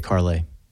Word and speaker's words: Carlay: I say Carlay. – I say yeah. Carlay too. It's you can find her on Instagram. It's at Carlay Ream Neal. CarlayReamNeal. But that's how I pronounce it as well.